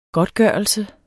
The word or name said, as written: godtgørelse